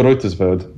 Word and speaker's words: Reutersvärd: Oscar Reutersvärd, I believe, is the pronunciation.